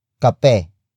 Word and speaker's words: kape: It is the focus marker, indicating that the next word is the focus of this sentence, which is kape. Kape. Kape. Kape is spelled as K-A-P-E.